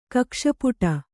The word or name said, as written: ಕಕ್ಷಪುಟ